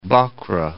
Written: Bhakra Dam

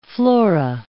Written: flora

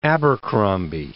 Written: Abercromby

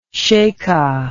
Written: sheika

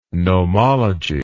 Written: nomology

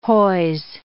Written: hoise

